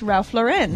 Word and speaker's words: Lauren: Just say Lauren. Don't say your name bougily. But anyway. – That's how you say his name. It's Ralph Lauren.